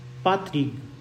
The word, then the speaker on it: Patrick: Porque, na verdade, o nome dele é um nome é, bretão, é, que se pronuncia algo como pa, Patrick. Patrick.